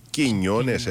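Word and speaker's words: Quiñones: Προηγήθηκε νωρίς το παιχνίδι, τον καθάρισε με τον Χιμένες, προηγήθηκε με τον Κινιώνες, έτσι προφέρεται αυτό.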